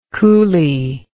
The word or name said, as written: coolie